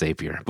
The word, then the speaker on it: Zapier: i know it's supposed to be pronounced zapier but what are your feelings on this did they just misspell it i think they, they with...